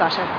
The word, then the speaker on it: Asha: My name's Asha.